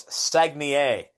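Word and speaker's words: Saguenéens: Well, as best as my English tongue can say it, it's Sagnier.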